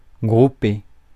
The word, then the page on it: grouper: France; /ɡʁu.pe/